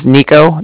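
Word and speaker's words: Niko: It was Nico. That's N-I-K-O. – Okay. – And then the Bank of Cardiff is spelled C-A-R-D-I-F-F, as in Frank, Frank.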